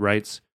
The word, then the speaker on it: Reitz: And Writes is spelled R-E-I-T-Z. Pronounced Writes.